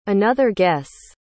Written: another guess

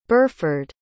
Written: burhford

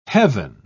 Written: heaven

